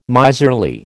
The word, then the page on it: miserly: American English